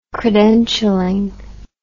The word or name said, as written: credentialling